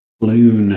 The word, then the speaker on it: Floon: Floon.